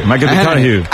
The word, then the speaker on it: McConaughey: Matthew McConaughey. – Sí. – Que hay que ir corrigiendo la pronunciación. – McConaughey.